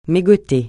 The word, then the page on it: mégoter: francais